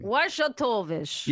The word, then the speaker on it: Wojtowicz: But it's a story of John Wojtowicz. That's a difficult to pronounce Polish name. – Wojtowicz.